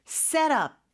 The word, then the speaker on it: set up: 연음이 되면서 set up, set up, T 발음이 리을처럼 됩니다.